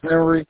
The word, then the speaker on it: Henry: My name is Henry Clinton.